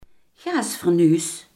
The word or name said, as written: gasfornuis